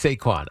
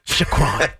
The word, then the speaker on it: Saquon: Sometimes there but it is capital S-A-Y, which means that's the emphasis. – Say. – And then dash, lowercase Saquon. K-W-O-N. – You trail off a little bit. – Saquon. – Emphasize the first syllable, yes. Trail off, show a little respect to the second.